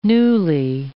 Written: newly

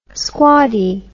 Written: squaddie

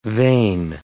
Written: vane

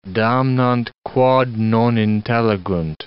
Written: damnant quod non intelligunt

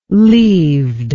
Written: leaved

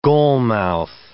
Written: goalmouth

